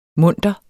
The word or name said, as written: munter